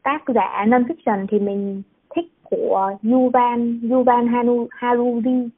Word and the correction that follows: Yuval: không uh, không biết đọc đúng tên của bác không nữa nhưng mà ừ. Yuval ở đúng rồi